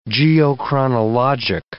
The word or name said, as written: geochronologic